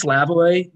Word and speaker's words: Slavoj: It's pronounced Slavoj, but it's here, I'll spell it out.